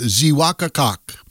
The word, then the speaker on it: Xeewakacak: Franks Hill, or in Native American, it's pronounced Zewakakak. That's spelled X-E-E-W-A-K-A-C-A-K. Meaning sacred hills.